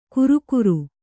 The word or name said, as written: ಘುರುಘುರು